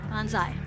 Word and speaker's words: banzai: Banzai.